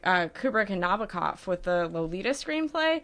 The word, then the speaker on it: Nabokov: what Nabokov said mm-hmm. is it rhymes with knockoff.